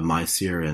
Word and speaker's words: Maisir: I'm going to mispronounce them, right? Riba, Maisir, and Garar. Yeah. Did I pronounce that properly? – Yeah.